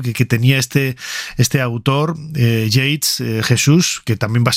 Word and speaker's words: Yeats: ¿Y esto cómo se pronuncia? ¿Y esto? Yeats. Pues hemos buscado en ese canal de pronunciación de YouTube que es enriquecedor absolutamente, y bueno, es William Butler Yeats, así que así lo vamos a llamar.